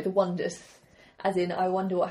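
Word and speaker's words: Oneders: Originally it's spelled O-N-E-D-E-R, as in the one... Mm-hmm. But no one could pronounce it, so they changed it to The Wonders, spelled correctly. They're brilliant.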